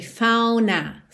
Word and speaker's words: found a way: Aqui ficou bem interessante found a way, ela não pronunciou realmente o D. Então tem found a way, fauna, fauna, jun, fauna, juntando no N.